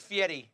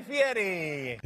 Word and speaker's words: Fieri: Here's how you say it. Hold on, I found a video. Here we go. – With a name like Fieri. – Ladies and gentlemen, here he is, Guy Fieri.